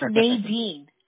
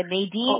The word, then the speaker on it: Nadine: Mi nombre es Nadine. No hay modo como pronunciarlo en español. Le digo a mi mamá que fue un castigo que me dio un nombre así. It's Nadine. Así se dice, Nadine.